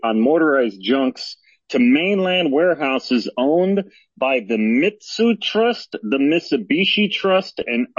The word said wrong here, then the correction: Mitsui: I think that would be pronounced, one more, I think that's Mitsui, M-I-T-S-U-I, is that correct? – Correct.